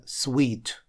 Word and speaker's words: suite: Следующее слово читается sweet. И очень многие его путают со словом suit. Но, друзья, suit – это у нас костюм. И он без буковки «и» в конце этого слова, а вот «и» в конце дает произношение sweet.